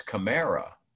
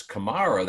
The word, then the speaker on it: Kamara: I was on a phone call yesterday with someone else whose first name is Kamara, and she pronounces Kamara. Mm-hmm. But on the phonetics chart, when I'm announcing the Saints, it's Kamara. – Kamara. – And she wanted me to remind him that it's Kamara.